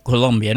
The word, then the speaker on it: Columbia: Esto es District of Columbia, se escribe Columbia, pero se lee Colombia, ¿no?